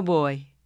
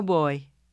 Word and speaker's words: voy: Yo no voy. In Spanish, the word boy is sometimes pronounced with a B and sometimes with a V, and it's regional, and either way is okay.